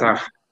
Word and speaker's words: Nassar: Nassar. Nassar.